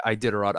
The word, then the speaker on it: Iditarod: No, yeah, Iditarod.